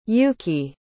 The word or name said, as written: yuuki